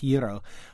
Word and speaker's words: gyro: The actual Greek consonant doesn't exist in English, and I can't quite do it, but something like gyro.